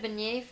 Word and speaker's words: beignet: I tried a beignet for the first time.